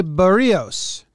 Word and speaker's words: Berríos: Jose Berríos.